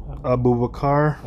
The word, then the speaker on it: Abubakar: Abubakar.